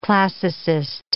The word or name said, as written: classicist